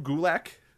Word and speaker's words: Gulak: Drew Gulak. – Okay. Rhymes with attack.